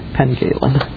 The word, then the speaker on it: Pencaitland: And after the upheaval of 1638, Calderwood became a minister again, this time in, Simon, correct my pronunciation on this, if you will, Pencaitland. It's just spelled just like I said it, Pencaitland.